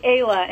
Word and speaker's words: Ayla: It's actually Ayla, and you spell it A-Y-L-A.